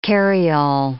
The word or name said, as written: carryall